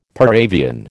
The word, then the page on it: par avion: American English